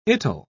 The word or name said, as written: it'll